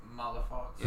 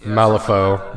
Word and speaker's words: Malifaux: Is it Malifox? Is that how you say it? – Let me see. – Malifaux. – Malifaux.